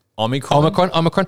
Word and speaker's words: Omicron: Now, because of the fact that the Omicron, am I saying that right? – Omicron.